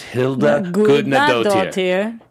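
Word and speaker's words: Hildur Guðnadóttir: I'm adding four extra syllables. It's Hilda Gudnadottir.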